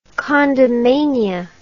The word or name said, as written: condomania